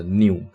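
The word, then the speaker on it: Nu: 当然啦，这个是跳过希腊字母中间的 New 跟 Xi 哦，就是 New 可以跳过的理由，应该是因为它的发音跟英文里面的 New 就是新，哦。那所以整天讲到新的病毒，就说哦，new virus 这样子，那会跟我们命名希腊字母 new virus 这个会有一点点呃混淆的状况。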